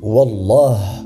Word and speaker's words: Wallah: La façon correcte de le prononcer est de dire Wallah, et le mieux est de ne pas jurer pour des choses futiles.